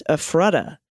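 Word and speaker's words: Ephrata: Efrata is pronounced Efrata.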